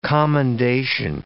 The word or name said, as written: commendation